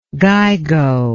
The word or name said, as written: gigo